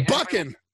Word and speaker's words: Buchan: And I'm probably going to butcher his name, but John Buchan, welcome to the show. – And it's thank you very much, man. It's Buchan, but don't worry. – Buchan, of course.